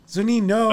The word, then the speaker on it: Zunino: If you can't get, oh my God, we've gone through an entire career of Zunino, and you still don't pronounce his name right. Zunino. – I don't care. – There's no U at the end.